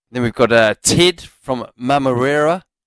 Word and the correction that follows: Manurewa: Have I said that correctly? – Well, that's how it's written. It could be Manurewa. – Manurewa.